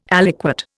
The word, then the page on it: aliquot: Indian